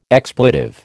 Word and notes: expletive: American English

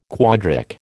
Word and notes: quadric: American English

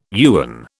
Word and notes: yuan: British English

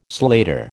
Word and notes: slater: American English

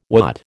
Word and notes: wot: American English

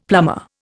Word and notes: plumber: Indian

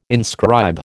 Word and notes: inscribe: American English

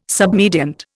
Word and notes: submediant: Indian

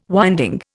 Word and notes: winding: Indian

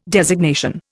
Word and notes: designation: Indian

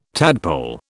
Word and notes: tadpole: British English

